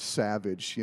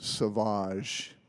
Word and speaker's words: Sauvage: So when I got to the next airport, I called it Savage. You know, I didn't know I was supposed to pronounce it Sauvage.